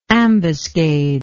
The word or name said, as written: ambuscader